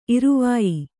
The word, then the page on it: ಇರುವಾಯಿ: iruvāyi